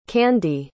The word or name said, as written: Kandee